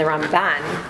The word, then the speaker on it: Ramban: So in case you've ever heard of Nachmanides, and that's called the Ramban, so the way that you can hear the difference is Rambam has the accent on the first part of his name, and the Ramban has the accent on the second part of his name. So because they sound so similar, the accents are different, so you'll be able to recognize it when you hear it. Rambam, Ramban.